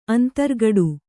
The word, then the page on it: ಅಂತರ್ಗಡು: kannada